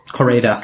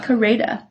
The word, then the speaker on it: Koreeda: Koreeda. – Koreeda.